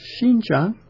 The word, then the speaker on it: Xinjiang: Gansu, as you go west of Yumen Pass, you enter Xinjiang province, pronounced Xinjiang,